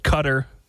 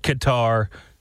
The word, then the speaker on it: Qatar: Qatar. I've heard people pronounce it Cutter. I don't know what it is. I pronounce it Qatar, similar to guitar, with a G sound, hard G.